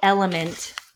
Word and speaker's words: LMNT: This is called Element, but it's spelled L-M-N-T.